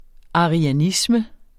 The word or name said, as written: arianisme